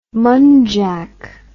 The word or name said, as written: munjak